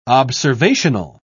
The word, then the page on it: observational: British English